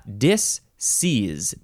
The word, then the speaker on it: disseise: Now we have, I guess it's pronounced disease. Yeah, disease, disease. You can spell it D-I-S-S-E-I-S-E, or the last S can be replaced with a Z, so it's disease.